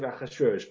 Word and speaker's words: Ahasuerus: That's the way it's written in the Megillah. We pronounce it Ahasuerus, but the Ketiv Kri there is Ahasrash.